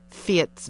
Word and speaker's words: Fiet: Alison Leslie Gold's new book, Fietz Vaz, is also about risk, danger, and generosity in a terrible time. Fiet is spelled F-I-E-T. It's a Dutch nickname.